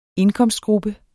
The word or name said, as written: indkomstgruppe